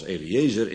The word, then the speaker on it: Eliezer: En in nog ander geval is Eleazar, uitgesproken als Eliezer in het Nederlands, de zoon van Abraham.